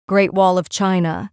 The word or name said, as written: Great Wall of China